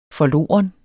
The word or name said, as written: forloren